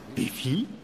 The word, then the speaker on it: Wi-Fi: Wifi oder Wifi? – Entweder geht's da um Hunde oder es ist was Französisches. – Sonic weiß es besser. – Das wird Wi-Fi ausgesprochen, Ralph.